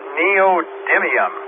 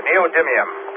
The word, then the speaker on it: neodymium: The magnet of choice of today, of course, is the new super duper magnets, pronounced as neodymium. There you go, neodymium. Got it right.